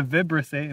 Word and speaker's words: vibrissae: They'll rub it with their vibrissae, which, by the way, the vibrissae, am I saying that right? – Yeah. I think so.